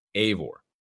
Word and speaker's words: Eivor: First off, too, Ashraf over on Twitter says that it's spelled E-I-V-O-R and pronounced Avor, A-Y.